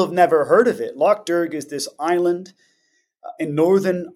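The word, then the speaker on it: Lough Derg: Am I pronouncing that correctly? – That's correct. Loch Derg.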